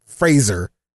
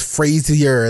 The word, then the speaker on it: Fraser: I'm trying to say his name right because it's not Fraser. It's Frazier.